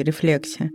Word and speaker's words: рефлексия: Словари дают ударение на втором слоге рефлексия.